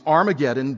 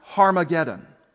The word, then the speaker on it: Harmageddon: Now the English rendering of the Hebrew term that John uses is Armageddon, but in its Hebrew, it's a compound word, which we would pronounce Harmageddon. Har is the first word, Mageddon, the second word.